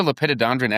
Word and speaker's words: Lepidodendron: It's Lepidodendron, I believe is how you pronounce that. Lepidodendron. – As a Lepidodendron expert, I think you said it right.